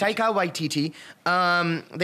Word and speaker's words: Taika Waititi: Is it Taika? – Yeah, Taika Waititi. It's Taika Waititi.